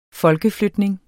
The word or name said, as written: folkeflytning